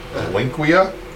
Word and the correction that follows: Reliquia: Reliquia? – Reliquia. Man, yeah, I like that better. – I think the clown won. – We'll go with Reliquia.